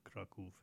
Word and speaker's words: Kraków: That's the Jewish pronunciation of Krakow. Krakow, we call it Kraków in Polish. Kroke in Yiddish, I guess.